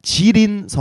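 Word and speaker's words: 지린 (吉林): 네, 그리고 지린 성이 있는데 길림성이라고 한국어로 발음하고 그다음에 해룽장 성이 있는데 흑룡강 성이라고 네네.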